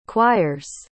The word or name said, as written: choirs